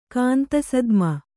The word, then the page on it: ಕಾಂತಸದ್ಮ: Kannada